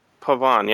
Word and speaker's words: pavane: Pavan, yeah. How do you spell that? – P-A-V-A-N-E by someone named Keith Roberts.